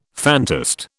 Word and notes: phantast: British English

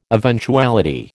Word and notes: eventuality: American English

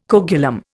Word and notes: coagulum: Indian

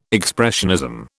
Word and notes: expressionism: British English

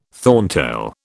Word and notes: thorntail: British English

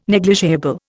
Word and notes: negligible: Indian

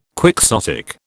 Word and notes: quixotic: British English

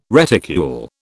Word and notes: reticule: British English

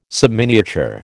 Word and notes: subminiature: American English

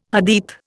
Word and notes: Hadith: Indian

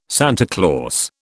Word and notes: Santa Claus: British English

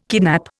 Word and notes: kidnap: Indian

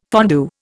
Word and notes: fondue: Indian

